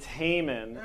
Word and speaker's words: Haman: Rhymes with Raymond. – Yes. It's Haman.